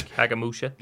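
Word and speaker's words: Kagemusha: There's Kagamusha.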